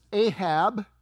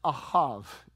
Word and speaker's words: Ahab: Now, most of us pronounce this Ahab, but it's Ahav in Hebrew and in Israel.